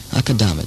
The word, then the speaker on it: Akadama: Akadama, it's spelled Akadama, A-K-A-D-A-M-A, and it rhymes with mama. Akadama wines are imported by Suntory International in Los Angeles. Suntory. S-U-N.